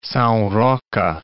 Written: Sao Roque, Cape

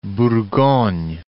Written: Bourgogne

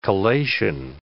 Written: collation

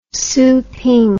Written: szeping

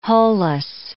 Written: hull-less

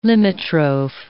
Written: limitrophe

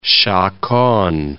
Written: chaconne

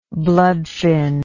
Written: bloodfin

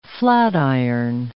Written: flatiron

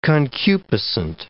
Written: concupiscent

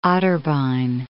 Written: Otterbein

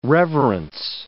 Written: reverence

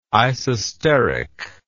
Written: isosteric

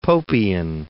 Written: Popean

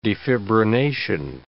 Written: defibrination

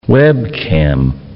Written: webcam

